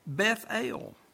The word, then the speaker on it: Bethel: And he called the name of that place Beth-El." We have the American pronunciation of that word, Bethel.